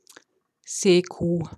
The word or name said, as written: Seekuh